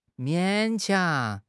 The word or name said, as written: 勉强